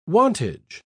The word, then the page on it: wantage: British English